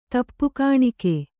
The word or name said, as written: ತಪ್ಪುಗಾಣಿಕೆ